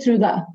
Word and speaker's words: Trude: No, my name is Trude. – Trude. – Trude is fine.